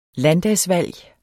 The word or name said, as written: landdagsvalg